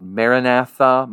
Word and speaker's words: Maranatha: And maybe you've never heard this word before, or you've heard it vaguely within the Christian circles, and it's Maranatha. Some people will pronounce it Maranatha, Maranatha.